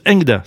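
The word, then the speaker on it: Engda (恒大): En chinois, son nom se prononce Engda, ce qui signifie grand pour toujours.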